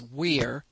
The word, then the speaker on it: vir: The word vir, which is Latin, these are pronounced like W's, vir is the word.